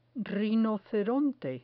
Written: rinoceronte

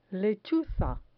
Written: lechuza

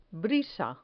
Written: brisa

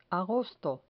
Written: agosto

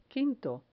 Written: quinto

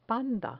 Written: panda

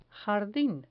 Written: jardin

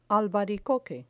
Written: albaricoque

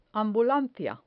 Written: ambulancia

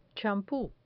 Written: champu

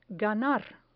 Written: ganar

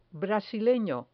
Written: brasileno

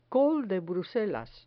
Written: col de bruselas

